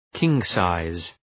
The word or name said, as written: king-size